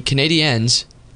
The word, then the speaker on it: Canadiens: So as of right now, the Canadiens, because that's how you have to pronounce it, are playing the Flyers.